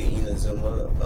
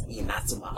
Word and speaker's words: Inazuma: Yeah, it's not actually Inazuma 11. That's more like the American way of saying it. – Okay. – It's Inazuma. – Oh, Inazuma 11. – The stress is on the second syllable.